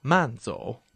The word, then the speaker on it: 慢: Manzo means walk slowly or take care on your way back home. The pronunciation of man is similar to that of the English word man, the fourth tone.